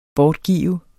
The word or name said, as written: BORTGIVES